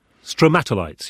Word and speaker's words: stromatolites: Stromatolites? – Stromatolites, yeah.